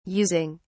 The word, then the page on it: using: British English